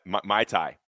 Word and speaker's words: Muay Thai: The ADCC World Championships was the weekend he submitted this, and that's like grappling and submission stuff, real wrestling, judo, and Muay Thai. Am I saying that right? Muay Thai.